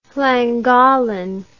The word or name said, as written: Llangollen